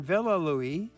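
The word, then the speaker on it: Villa Louis: And today we're going to zero in a bit on Villa Louie. I don't even know if I'm pronouncing that right. – You are pronouncing it correctly.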